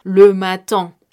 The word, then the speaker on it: le matin: En este caso yo estoy haciendo referencia al periodo de tiempo que es le matin, le matin. Ahora, esta palabra se escribe el E por el artículo, espacio M-A-T-I-N.